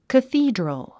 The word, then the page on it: cathedral: kəθíːdrəl; カスィードロゥ